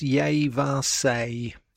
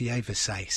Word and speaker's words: Yvaes: It's Yevasay. It's spelt Y-V-A-E-S. But I'm told it's pronounced Yevasay.